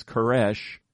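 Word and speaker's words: Koresh (כורש): The Hebrew pronunciation is Koresh. My Iranian friend Donya wrote the Persian script for Cyrus' name in my Bible here at this verse at 44:28, and told me to pronounce it as Kurosh.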